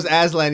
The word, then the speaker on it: Aslan: That's like that Aslan. I probably butchered his name. Chronicles of Narnia? – Yeah, Aslan.